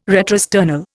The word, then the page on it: retrosternal: Indian